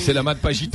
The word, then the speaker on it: Selamat Pagi: c'est Selamat Pagi Tangi, tu Non, non, c'est bien, c'est bien.